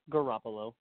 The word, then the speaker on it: Garoppolo: I just, looking at it, you got Jimmy G, Jimmy Garoppolo, that's how you say it, Garoppolo.